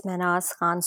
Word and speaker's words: Mehnaz Khan: It's Mehnaz Khan Studio.